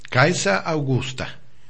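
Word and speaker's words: Caesaraugusta: Caesa Augusta. Así se pronuncia en latín.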